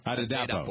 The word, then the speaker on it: Adepo: Naomi Adepo. Is that how you pronounce her name? A-D-E. – Naomi Adepo. Adepo.